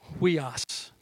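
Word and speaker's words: Huios (υἱός): Huios, that's how you pronounce that.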